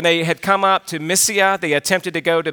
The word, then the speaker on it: Mysia: I'm just really butchering that word, right? I'm going to go to a doctoral candidate. Josh Dojero, how do you say that word? Mysia. All right, there we go. I got it.